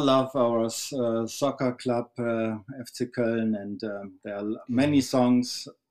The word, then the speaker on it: Köln: We were discussing that Köln and Cologne is the same, and how to pronounce it. Köln. The people from Köln they say more Köller. I'm from Köller, so that's a dialect.